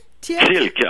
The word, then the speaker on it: Thielke: Thielke, alors exactement en français, c'est un nom d'origine allemand.